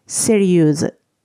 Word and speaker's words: sérieuse: もともとの形が最後がいくつで終わるセリュそして今回のは女性形なので、いくつの代わりに su となっていて、セリューズとなります。発音変わるんですよね。